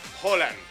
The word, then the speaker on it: Haaland: ¿Se pronuncia Haaland o Holland o Hilland o Hulan o...? Bueno, Alfredo González, comentarista de Fórmula Deportes, ¿cómo se pronuncia? – Holland. – Holland. – Holland. – Es con O.